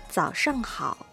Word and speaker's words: 早上好: これは、早上好。